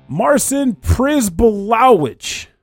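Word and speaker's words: Marcin Przybyłowicz: And the track is titled Blood on the Cobblestones, composed by name I'm going to mispronounce, Marcin Przybylowicz. – Nailed it. I'm going to tell you right now, you nailed it.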